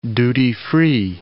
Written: duty-free

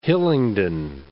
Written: Hillingdon

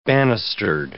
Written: banistered

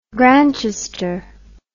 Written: grantchester

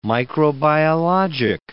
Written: microbiologic

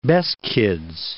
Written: Beskids

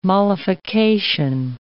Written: mollification

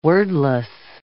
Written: wordless

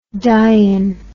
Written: dyon

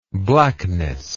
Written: blackness

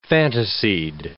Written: fantasied

